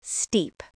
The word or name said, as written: steep